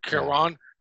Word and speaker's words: Cuarón: Alfonso Cuarón, am I saying his name right? – Cuarón, who did Children of Men and Gravity.